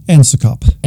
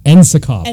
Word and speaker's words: NSICoP: And we've just now settled that the actual pronunciation should be NSICoP. – NSICoP. – NSICoP. That's right.